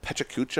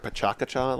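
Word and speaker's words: PechaKucha: I had a friend of a friend that there's this thing called Pechacucha or Pachacacha, like you pronounce it both ways,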